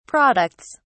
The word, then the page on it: products: British English